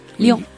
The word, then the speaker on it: Lyon: ¿Cómo se dice? – Lyon. – Oui. Lyon. – Nasal.